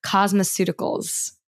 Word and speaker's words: cosmeceuticals: correct me if I'm pronouncing this incorrectly, cosmeceuticals. Was that the correct pronunciation? – Yeah.